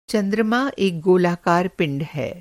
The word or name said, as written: गोलाकार